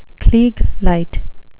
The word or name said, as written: klieg light